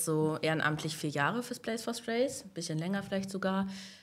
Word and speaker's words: Place for Strays: Und zwar geht es um den Verein Place for Strays, spricht man das richtig so aus? Ja, ja also mhm.